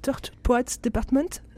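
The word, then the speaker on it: The Tortured Poets Department: C'est Tort Poets Department, ouais, c'est comme ça qu'on dit, ouais.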